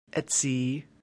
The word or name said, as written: etsi